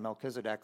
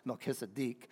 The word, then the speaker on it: Melchizedek: I've been saying Melchizedek all the time. It's actually pronounced Melchizedek.